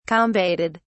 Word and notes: combated: British English

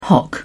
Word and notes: hock: British English

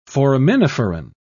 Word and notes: foraminiferan: British English

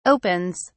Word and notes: opens: British English

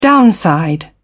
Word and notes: downside: British English

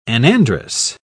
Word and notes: anandrous: British English

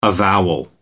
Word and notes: avowal: American English

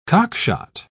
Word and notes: cockshot: British English

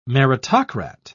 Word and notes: meritocrat: British English

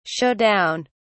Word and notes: show down: British English

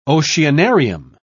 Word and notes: oceanarium: British English

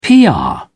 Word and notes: pr: British English

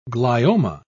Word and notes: glioma: British English

